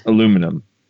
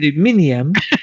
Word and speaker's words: aluminium: It's just aluminum. – No, that's wrong. – It's aluminium.